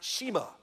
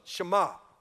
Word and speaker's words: Shema: The verb "hear" in the Old Testament is pronounced Shema, Shema.